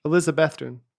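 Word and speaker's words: Elizabethton: I know the one in Tennessee, that's how you pronounce it. – How do you pronounce it? – Elizabethan.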